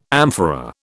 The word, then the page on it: amphora: British English